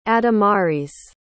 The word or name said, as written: adamaris